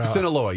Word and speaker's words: Sinaloa: Sinaloa? I don't know if I'm pronouncing it correctly. Colima is where... – Sinaloa, yes. I'm sorry.